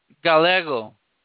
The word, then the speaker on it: Gallego: It's a GALLEGO. – Gallego? – Gallego.